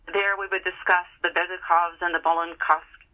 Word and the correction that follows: Bolkonskys: Pronunciation here. I'm going to mess up all these names. The Bolkonskys,